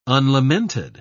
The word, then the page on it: unlamented: British English